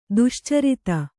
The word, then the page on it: ದುಶ್ಚರಿತೆ: duścarite